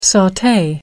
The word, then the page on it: saute: American English